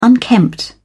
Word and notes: unkempt: British English

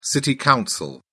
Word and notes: city council: British English

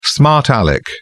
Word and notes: smart alec: British English

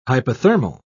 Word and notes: hypothermal: British English

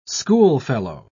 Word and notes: schoolfellow: British English

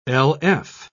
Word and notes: lf: British English

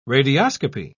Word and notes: radioscopy: British English

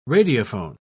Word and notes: radiophone: British English

